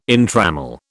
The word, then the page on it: entrammel: British English